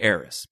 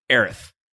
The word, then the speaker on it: Aerith: we too shall say Eris. But I just, for the record, just need to say her name officially is Aerith. You all right? – Yeah, it just sounds, I mean, it sounds like a lisp, which is... – It doesn't sound right. It's Eris. – Yeah. A-E-R-I-S.